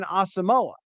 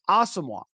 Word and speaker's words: Asamoah: was known as Brian Asamoah. But Brian himself asked the public relations to pass along that his name is pronounced Asamoah.